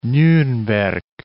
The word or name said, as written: Nurnberg